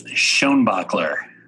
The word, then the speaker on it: Schoenbaechler: It is Schoenbaechler.